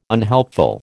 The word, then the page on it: unhelpful: American English